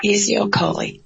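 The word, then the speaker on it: Ezeokoli: and the last name is spelled E-Z-O-K-O-L-I. – E-Z-E-O-K-O-L-I. E-Z-E-O-K-O-L-I. – Ezekoli. Okay. – Ezekoli. – And Shola Shadi. Shadi is spelled S-H-A-D-E.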